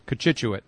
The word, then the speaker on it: Cochituate: Kachituate.